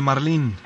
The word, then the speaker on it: marlín: Entonces, con énfasis en la última sílaba, y ortográficamente, como termina en n, se acentúa en la i, marlin. Uh-huh. No lo consigna aún el DRAE, pero los expertos así lo han propuesto, y aunque pues aquí debemos decir que la pronunciación difundida es marlin, ¿verdad?, como en inglés.